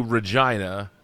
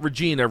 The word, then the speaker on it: Regina: But we were going, uh, we were driving from Saskatoon to Regina, and they pronounce it not Regina, Regina. They call it the city that rhymes with fun.